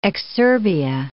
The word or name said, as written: exurbia